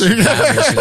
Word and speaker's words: Habish: Yeah, Habish. Habish is how I say my last name. In Arabic, it's just HBSH.